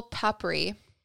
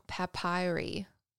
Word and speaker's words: papyri: This folktale is inscribed magical papyri. I'm saying that wrong. Papyri. Just paper, basically.